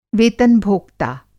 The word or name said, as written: वेतन-भोक्ता